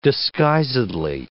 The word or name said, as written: disguisedly